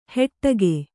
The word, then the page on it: ಹೆಟ್ಟಗೆ: heṭṭage